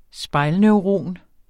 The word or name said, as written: spejlneuron